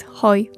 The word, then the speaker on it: hoi: Musiałam posłuchać Adama i dzięki niemu wiem, że to jest hoi. Pisze się przez samo H. H-O-I. – Hoi.